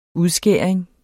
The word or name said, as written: udskæring